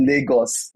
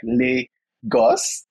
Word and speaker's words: Lagos: Like, I was waiting for you to say Lagos, because most times everyone always messes up like the pronunciation. So it's actually Lagos, like Lagos.